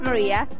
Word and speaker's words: Maria: Leslie, and it's L-E-S-L-E-Y. – Hi, my name is Joanne. What's your name? – Maria. – Maria, can you spell that? – M-A-R-I-A.